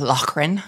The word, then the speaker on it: Loughran: So my name is Janet Loughran. I know that a lot of people don't know how to pronounce it, but it's Loughran. My husband is very Scottish.